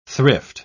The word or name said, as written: thrift